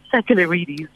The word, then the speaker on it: Sakellarides: And yes, you did almost pronounce it correctly. It's Sakellarides, the Greek name.